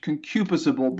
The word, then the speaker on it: concupiscible: But the word is properly pronounced in English, concupiscible.